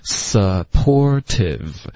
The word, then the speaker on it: supportive: 对，后面最后一个字 supportive，这个特的音在美式的发音会浊化成一个的的音，supportive。